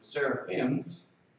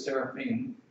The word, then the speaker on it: seraphim: Now, our translators have rendered it seraphims, but really it's seraphim, or more properly pronounced, seraphim.